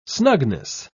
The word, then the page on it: snugness: British English